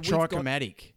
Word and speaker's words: trichromatic: So, humans are what's called trichomates. But pardon my pronunciation. So trichromatic.